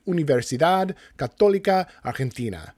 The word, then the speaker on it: Universidad Católica Argentina: He earned his master's from Westminster Choir College, and his undergrad degrees in choral conducting and composition are from the Universidad Católica Argentina.